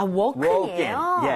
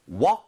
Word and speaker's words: woke: walk 하지 마세요. 그거는 걷다잖아요. – 아, walk. – W A L K, right? walk. So, I woke up 이렇게 하지 마시고요. 응. I woke up. 거의 5에 가깝게 발음하는 와, 겁니다.